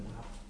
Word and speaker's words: nạp: À, chữ đó không đọc là nội mà đọc là nội. – À, đây là nội mà đây là nạp.